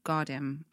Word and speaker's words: Jardim: is it Jardim or Gardim? I always say it wrong.